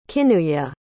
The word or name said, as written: kinuye